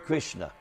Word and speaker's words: Krishna: Krishna. Krishna means it's two syllables.